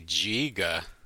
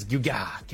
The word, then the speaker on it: Geauga: Wait, here's the thing. It's actually Giga.